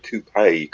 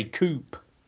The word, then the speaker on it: coupé: Yeah, you lot say coupe. – Well, does it have an accent aigu on it? – Yeah, it does. – Like the car? – Yeah. A coupé. Yeah, it does. On the E, there's an accent on the E.